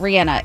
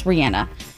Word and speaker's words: Rihanna: But I pronounce it Rihanna. It's Rihanna. Ellen asked her specifically, and she answered.